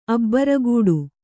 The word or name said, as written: ಅಬ್ರೂಗೇಡಿ